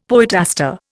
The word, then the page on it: poetaster: Indian